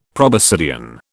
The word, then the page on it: proboscidean: British English